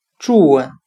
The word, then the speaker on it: June: 今天我们来学习单词“ 祝稳 ”（J-U-N-E），表示六月的含义。我们用谐音法来记忆这个单词“ 祝稳”，它的发音很像汉语的“ 祝恩”，庆祝的“ 祝”。